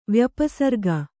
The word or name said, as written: ವ್ಯಪಸರ್ಗ